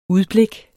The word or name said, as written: udblik